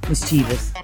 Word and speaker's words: mischievous: Mischievous. – Mischievous, you yeah. Pronounce it. Not mischievous. – No. – It actually has three syllables, not four, and it actually is pronounced...